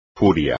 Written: furia

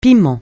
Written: piment

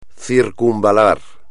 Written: circunvalar

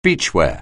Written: beachwear

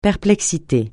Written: perplexité

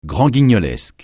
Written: grand-guignolesque

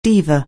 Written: diva

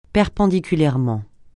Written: perpendiculairement

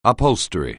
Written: upholstery

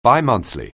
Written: bimonthly

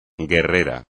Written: guerrera

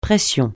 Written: pression

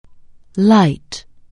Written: light

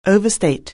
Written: overstate

